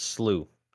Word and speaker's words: slew: It's slew, not sleut. – Oh, sleuth footed. My bad. – Slew. He said it's sploosh. – Like the flu.